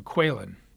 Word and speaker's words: Qualen: His grandfather changed the family name from Olesen to Kvalen, K-V-A-L-E-N. His father changed the spelling to Q-U-A-L-E-N, but still pronounced it Kvalen. John is the one who changed the pronunciation to Quaylen.